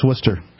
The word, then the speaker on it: Worcester: How do you pronounce Worcester? – Worcester. No H.